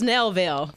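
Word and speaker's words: Snellville: Snailville. Oh. Yeah. And you have to say it like that too.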